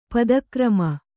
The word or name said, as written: ಪದಕ್ರಮ